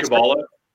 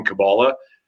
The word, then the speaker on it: Kabbalah: You're saying Kabbalah, right? Are you saying Kabbalah? – That's the one. So our pronunciations are slightly different.